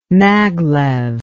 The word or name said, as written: maglev